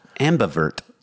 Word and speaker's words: ambivert: It's called ambivert, A-M-B-I-vert, ambivert. Is that how you say it? – Mm-hmm.